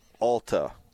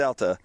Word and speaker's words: Alta: You know how you know somebody's not from Utah, like is a tourist, is because they say the word Alta. Because we all pronounce it Alta because it's pronounced Alta. But having worked in the hospitality industry in this town, you could spot anybody not from here says Alta. – With the emphasis on the T as well as the long A sound.